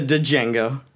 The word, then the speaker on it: Django: Also, Django, it's a Django. – Oh, that's right. But the D is silent.